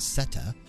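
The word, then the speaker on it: Ceta: We're going to kick things off with Seta, C-E-T-A. I think that's pronounced Seta, from Rimworld.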